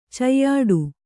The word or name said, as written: ಚಯ್ಯಾಟ